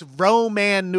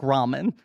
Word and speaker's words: ramen: He's like, the joke only works if you pronounce it that aggressively. And you can feel Ludacris saying, it doesn't make sense. That doesn't sound like ramen.